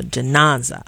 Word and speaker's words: Janaza: Janenza. Did I get it correct? – Janaza.